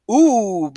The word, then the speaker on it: Ooh: And so one of Jones's most brilliant innovations was indeed an out in the form of an obstructionist ghost called O-O-O or Ooh. We actually had to discuss how to pronounce it on the audiobook. I'd always thought of this ghost as Ooh because it sounds more spooky.